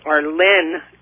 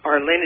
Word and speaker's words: Arlyne: And my name is Arlene Diamond. Arlene is spelled A-R-L-Y-N-E.